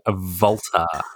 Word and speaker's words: WALTER: Walter, I mean, I preferred him when he was at the drive-in, but I thought you were saying Volta. – No, that's how you meant to say his name.